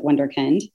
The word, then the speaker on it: Wunderkind: And I've found my way to Wunderkind. I pronounce it Wunderkind, which you do a much better job with that accent.